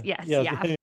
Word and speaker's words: YAF: Is that how it's pronounced? – Yes. YAF. So YAF is a right-wing student group.